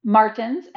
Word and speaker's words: Martens: Martins, M-A-R-T-E-N-S, and last name Dangond, D-A-N-G-O-N-D, and on Instagram, I am B Dangond.